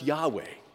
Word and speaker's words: Yahweh: In Hebrew, those four letters, YHWH, it's pronounced Yahweh.